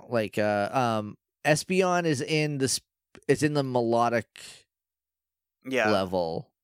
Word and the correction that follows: Milotic: Melodic. I've forgotten how to say it again. – Milotic. – Emphasizing the wrong syllable. – You really have to hit the T.